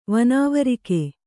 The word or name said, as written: ವನಾವರಿಕೆ